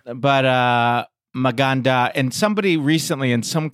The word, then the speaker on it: Maganda: So Meganda, Maganda, uh-huh.